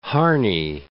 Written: Harney Lake

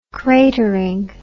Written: cratering

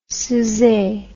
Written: Souzay

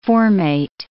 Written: formate

